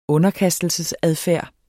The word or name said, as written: underkastelsesadfærd